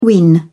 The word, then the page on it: win: British English